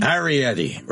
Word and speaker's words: Iorietti: Iorietti. Rhymes with spaghetti.